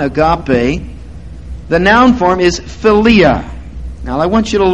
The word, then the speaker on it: philia: So the first one is eros, the second one is philia, with the emphasis on the second syllable. Philia, all right? Now, eros relates to the body. Philia relates to the soul, the personality.